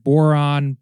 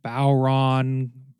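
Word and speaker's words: bodhrán: which is called the boron. It's pronounced a few different ways. I've heard boron, bowron, baron, bowron. It's spelled B-O-D-H-R-A-N, and there's an accent over the A.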